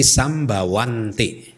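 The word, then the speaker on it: sambhavanti: Awija pacaya sangkara sambawanti karena sangkaranya jamak berarti sambawanti cara membacanya begitu.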